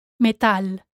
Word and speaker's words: metal: Metal, metal. Here, again, the stress falls on the A, metal.